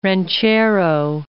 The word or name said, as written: ranchero